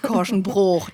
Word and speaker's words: Korschenbroich: Jutta Profeit ist verheiratet und wohnt mit vielen Tieren in Korschenbroich am Niederrhein. – Genau, es spricht sich Korschenbroch. – Das Korschenbroch, ist das, ach natürlich. – Rheinische Dehnungs-I. – Korschenbroch, wie Grevenbroch.